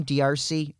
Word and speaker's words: D'Arcy: D'Arcy Carden. – Is that how you pronounce her name, D'Arcy? Or is it Darcy? – It is just Darcy.